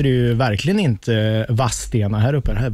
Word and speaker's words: Vadstena: Eh, jag läser upp här innan jag läser så ställer jag frågan, varför uttalar man det inte Vadstena och Vastena som det ska vara? Vadstena som... heter det ju. Ja, men varför inte Vadstena? – Mm. Det där är ju en dialektal grej.